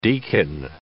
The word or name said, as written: deacon